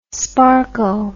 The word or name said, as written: sparko